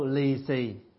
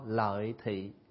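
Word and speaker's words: lì xì: So li si is a Chinese pronunciation. But the real, if we pronounce in a Vietnamese way, we call lai thi.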